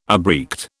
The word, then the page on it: abreact: British English